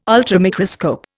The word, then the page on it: ultramicroscope: Indian